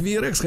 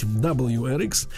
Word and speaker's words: WRX: На четвертом месте Subaru V, ну, его называют VRX, хотя правильно говорить WRX.